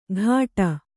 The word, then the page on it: ಘಾಟ: Kannada